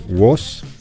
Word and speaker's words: Wos: Wos, ¿está bien? – Sí.